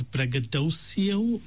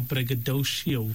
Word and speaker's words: braggadocio: The word we were looking for is braggadocio or braggadocio, however you want to pronounce it. So braggadocio. Or braggadocio. It's spelled B-R-A-G-G-A-D-O-C-I-O. So there's two A's, there's two G's, and there's two O's, three sets of doubles.